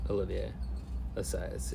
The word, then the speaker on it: Olivier Assayas: Oh yeah, Olivier Assayas, yeah.